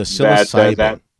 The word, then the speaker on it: psilocybin: Oh, the psilocybin.